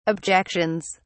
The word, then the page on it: objections: British English